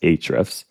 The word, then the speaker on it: Ahrefs: I love sitting there and like just digging through Ahrefs? I think it's just supposed to be pronounced Ahrefs. – I just call them Ahrefs, yeah.